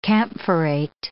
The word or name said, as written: camphorate